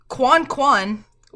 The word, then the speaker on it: coin coin: In French, geese go quan quan, which is spelled coin coin, like coin.